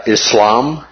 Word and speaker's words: Islam: All right, we are going to be looking at Islam. Islam is typically or commonly the way the emphasis goes on the syllable. – But Islam is the way it's more commonly pronounced by those who practice that.